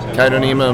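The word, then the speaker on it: Chaetocnema: Is that Cato Nema? – Yeah, I think it's like C. The C is silent. Cato Nema minuta.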